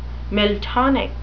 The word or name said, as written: miltonic